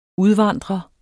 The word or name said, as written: udvandrer